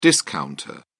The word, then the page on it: discounter: British English